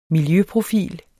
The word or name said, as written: miljøprofil